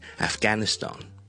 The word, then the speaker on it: Afghanistan: 我们先讲英式英文， BBC 通常呢，个发音呢，是会这么读的，大家听我读一次， Afghanistan, Afghanistan. Afghanistan，串法当然是 Afghanistan， Afghanistan。